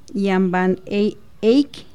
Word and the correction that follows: Jan van Eyck: no sé cómo se ah, eh, pronuncia. Jan Van Eyck.